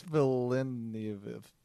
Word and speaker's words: Villeneuve: Dennis Villeneuve.